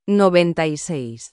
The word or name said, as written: 96